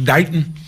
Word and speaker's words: Dayton: I grew up here, and I live in a suburb now, but this was home, although if you're really from Dayton, you know it's pronounced Dayton.